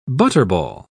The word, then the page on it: butterball: British English